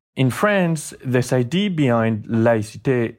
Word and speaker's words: laïcité: which is called laïcité. – And what is laïcité? Am I saying it correctly? Laïcité. – Laïcité, yeah. That's fine this way.